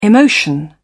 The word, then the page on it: emotion: British English